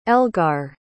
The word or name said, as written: elgar